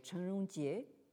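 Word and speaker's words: Chen Rongjie (陳榮捷): Si on le prononce donc en chinois standard, donc ce serait Chen Rongjie, et qui est né en 1901 et mort en 1994.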